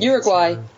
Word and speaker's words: Uruguay: Somehow she Uruguay. – Uruguay. – But somehow she gets her down there. Uruguay. – Yeah, Uruguay. I was like, what? Yeah, weird pronunciation.